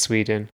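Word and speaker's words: Swedien: Oh, it's just pronounced Sweden. – Bruce Sweden. – I think. But there's an extra I in there Bruce. That I wasn't expecting.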